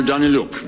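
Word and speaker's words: Danyliuk: I had a phone conversation with Alexander Danieluk. Mr. Danieluk. Mr. Danieluk. Mr. Danieluk. Alexander Danieluk. Mr. Danieluk. Mr. Danieluk understood. – That's Ambassador Taylor pronouncing correctly, apparently, that name.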